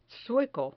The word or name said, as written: sueco